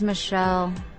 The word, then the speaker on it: Michele: My name is Michelle, spelled with one L.